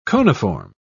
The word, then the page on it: coniform: British English